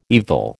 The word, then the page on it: evil: American English